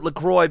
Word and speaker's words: LaCroix: They called it LaCroix because it rhymes with enjoy, because they're trying to push it like people enjoy LaCroix.